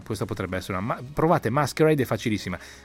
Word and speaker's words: MSQRD: Allora, l'app della settimana, cogliamo anche una notizia: Facebook, che ha un sacco di soldi, si è comprato un'app che si pronuncia Masquerade, anche se è scritta MSQRD, cioè hanno tolto le vocali, hanno tenuto Masquerade, e è un po' idiota, però ogni tanto va bene anche fare un po' di divertirsi.